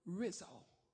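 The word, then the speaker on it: rhizo: Biblical meaning of root, when used as a verb, root derives from the Greek word rizo. Rizo. Or you can pronounce it rizo. R-H-I-Z-O-O.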